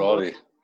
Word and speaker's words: Raleigh: Raleigh, wird ausgesprochen Raleigh.